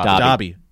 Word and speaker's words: Dobby: Dobby.